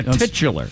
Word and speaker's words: titular: Yeah, titular. – Oh, titular. – Titular, okay. – Yes. – Okay, there should be a CH in there, but there isn't. – Well, that's how you pronounce it.